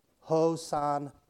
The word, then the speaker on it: Hosanna: Looking in the concordance, it's actually pronounced with an accent on the last syllable. Hosanna.